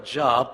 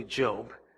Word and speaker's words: Job: Let's open our Bibles today to Job chapter 1, please. The book of Job chapter 1. That's in your Bibles. It is spelled like Job, but it's really Job.